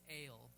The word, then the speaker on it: El: And it, the way that you would actually probably pronounce it if you were trying to be really accurate is ale, but everyone says El.